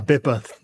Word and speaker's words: BIPA: Am I pronouncing that correct? Is it BIPA? Is that how you data privacy guys do it? – Yeah, BIPA. Yep.